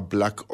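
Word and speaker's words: BLK: Son álbumes que salieron en las últimas semanas del 2023 y vamos a presentar a continuación a Black Odyssey. Se escribe medio raro, como que sin la A, B-L-K, Odyssey.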